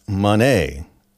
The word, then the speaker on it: Moné: So we saw the debut of Mercedes Moné, as it is pronounced,